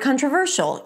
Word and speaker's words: controversial: So, let's take the word controversial. You know that the primary stress should be before the suffix. So it's the ver, controversial.